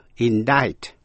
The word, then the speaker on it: indict: Và cuối cùng là C câm, tức là không phát âm, trong những chữ như indict, I-N-D-I-C-T, indict, indict, truy tố.